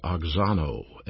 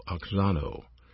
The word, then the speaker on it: auxano: This verb grow is pronounced oxano, A-U-X-A-N-O, oxano, translates grow.